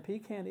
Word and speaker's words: pecan: and pecan is the proper pronunciation it had pecans in the yard.